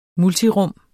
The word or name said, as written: multirum